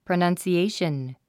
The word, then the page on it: pronunciation: prənʌ̀nsiéiʃən; プラナンスィエイション